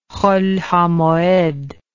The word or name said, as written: cholhamoed